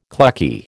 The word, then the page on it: clucky: American English